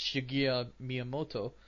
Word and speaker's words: Shigeru Miyamoto: I don't know how he pronounced it now, but uh, it's Shigeru Miyamoto, and. Uh, that's how I've heard it being pronou- pronounced many times over.